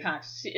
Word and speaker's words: Coxie: And then, to the shock of everyone, he confessed to Coxie. – Coxie. – It's spelled like Moxie, only with a C.